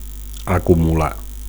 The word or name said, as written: acumular